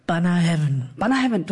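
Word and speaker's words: Bunnahabhain: Und die Antwort deiner Passion begegnete dir als Geist in der Flasche eines Banner Heaven, du hast das richtig ausgesprochen.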